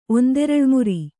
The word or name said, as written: ಒಂದೆರಳ್ಮುರಿ